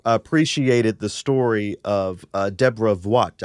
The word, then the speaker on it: Voigt: I think she says Voigt. I'm sure of it, because I've listened to her be introduced at the Met many times. Yes, it's Deborah Voigt. – Yeah, Deborah Voigt.